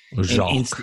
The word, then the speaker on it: Jacque: I think it's Jack is how you pronounce the first name. J-A-C-Q-U-E, Vaughn. – Jacques.